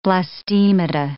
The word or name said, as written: blastemata